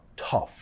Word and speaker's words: tough: Tough parece difícil de pronunciar, muchos vocales ahí, pero solo el sonido tough, tough.